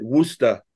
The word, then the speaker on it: Worcester: When I was 16, I did a fanzine, and I called it Worcester. W-O-O-S-T-A was the name of the zine. Because that's how people pronounce the city, yeah.